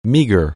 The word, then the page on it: meager: anglais